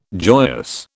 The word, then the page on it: joyous: British English